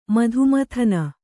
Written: ಮಧುಮಥನ

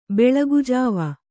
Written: ಬೆಳಗುಜಾವ

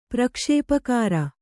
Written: ಪ್ರಕ್ಷೇಪಕಾರ

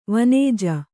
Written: ವನಜ

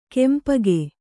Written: ಕೆಂಪೊಗೆ

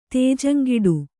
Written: ತೇಜಂಗೆಡು

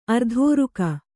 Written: ಆರ್ದರಕ

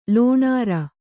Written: ಲೋಣಾರ

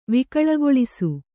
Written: ವಿಕಳಗೊಳಿಸು

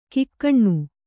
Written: ಘಕ್ಕನೆ

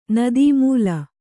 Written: ನದೀಮೂಲ

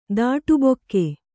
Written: ದಾಟುಬೊಕ್ಕೆ

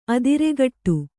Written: ಅದಿರೆಗಟ್ಟು

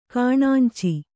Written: ಕಾಣಾಂಚಿ

